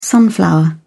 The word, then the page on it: sunflower: British English